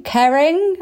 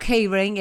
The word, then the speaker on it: Kering: And that is the news that Vestiaire have had a massive round of investment from, I'm going to say this wrong, I think, Kering. I got this wrong earlier. – Well done. Not Keyring.